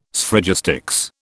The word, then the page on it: Sphragistics: British English